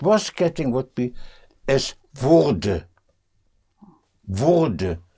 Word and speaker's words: Wurde: To make that sound distinction between Würde for would and Wurde for was getting, was becoming, was getting. So, mm. ah, it was mm. getting dark. Es, es wurde.